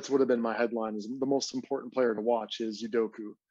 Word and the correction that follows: Udoka: If I'm pronouncing his name incorrectly, I apologize. But Udoka? Yeah. – Udoka, I think it is. Yeah. – Udoka. Yeah, that's right.